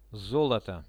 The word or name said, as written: zoloto